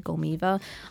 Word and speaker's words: Golmeeva: So it's a little different, but a last name is spelled just how you hear it, Golmiva.